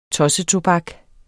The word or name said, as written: tossetobak